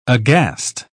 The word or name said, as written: aghast